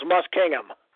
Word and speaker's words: Muskingum: M-U-S-K-I-N-G. – So it's Kirk versus Musking? – Yeah, Muskingum, then U-M. I'm sorry, U-M.